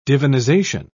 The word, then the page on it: divinization: British English